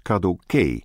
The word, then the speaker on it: Kadoké: De nadruk ligt op de derde lettergreep. Het is Kadoké.